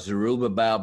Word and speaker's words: Zerubbabel: And it's called The End Times Temple, and it's about Zerubbabel.